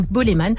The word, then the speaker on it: Bolémvn: Boleman, hein, pour la petite précision, ça s'écrit avec un V à la place euh, du A, mais ça se prononce Boleman.